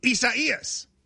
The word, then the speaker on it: Isaias: It looks like Isaiah, but without the H. – Spell it. Can you spell it for me? – I-S-A-I-A-S. – And it's four syllables. I do know that. I-S-A-I-A-S. And it's I-S-A-I-A-S.